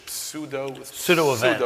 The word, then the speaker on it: pseudo-event: pseudo-event. – I think you don't pronounce a P, so to pseudo-events in America.